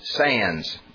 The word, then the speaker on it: Sandys: Well, it's pronounced S-A-N-D-Y-S in English. It's pronounced Sands. Looks like Sandys, but Edwin Sandys, S-A-N-D-Y-S, whose descendant was of Jamestown, Virginia fame.